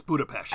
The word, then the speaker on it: Budapest: The way I've always pronounced it is Budapest, sort of like an SHT sound. But I will say that I'm not completely sure on that. – Well, you're correct. It's Budapest.